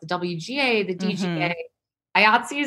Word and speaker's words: IATSE: IATSE, I like that. – I know some people pronounce it Yahtzee, though, and that's weird.